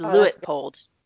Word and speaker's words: Leopold: So it's Leopold.